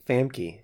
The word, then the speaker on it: Famke: Philly says it's pronounced Famke. – Famke? – Is it really? – Famke Jensen or Jansen? – It's like a European name. Okay, Famke sounds more believable than Famke.